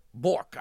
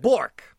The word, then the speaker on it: Bourque: This is from Tom Burke, B-O-U-R-Q-U-E. – Bork, I would say. – Bork. – Yeah. – Dear Hoosits. – Rhymes with jork.